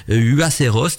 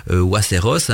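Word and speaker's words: Huaceros: Alors ces mystérieuses découvertes ont été euh, réalisées par euh, des euh, euh j'espère que je prononce bien, c'est Uaceros, donc c'est H-U-A-C-E-R-O-S, e, e, e, donc moi je veux dire Uaceros, hein.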